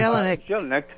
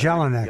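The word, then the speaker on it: Jellinek: How do you say his last name? How do you pronounce Jelinek. – It? – Jelinek. – Yeah. I've heard that, yeah.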